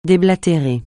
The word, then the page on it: déblatérer: French